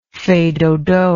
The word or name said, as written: fais dodo